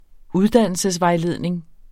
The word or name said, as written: uddannelsesvejledning